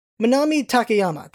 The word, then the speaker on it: Manami Takayama: Now for the Japanese version, the voice actor is Manami Takayama. Let me pronounce that again better. Manami Takayama. That's her name, and she has done a lot of voices.